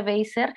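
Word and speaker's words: Wasser: Ella es Joan eh, Beiser o Weiser, se pronuncia Weiser, Este, pero justo su nombre artístico desde hace varios años es Joan as a Police Woman.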